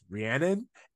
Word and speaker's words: Rhiannon: User DenBro710 pointed out that it's Rhiannon is the name of a song by Fleetwood Mac. And they were like, if you listen to that song, you will know how to say her name. So That is what she is named after. That will help with the pronunciation. So it's Rhiannon and not Riri.